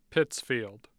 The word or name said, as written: pittsfield